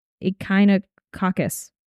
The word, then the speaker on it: echinococcus: His blood work was perfect, and they sent the fluid from the cysts off to be tested, where it came back as echinococcus.